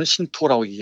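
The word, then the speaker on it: 신토라고 (神道): 일본식 발음으로는 신토라고 얘기합니다.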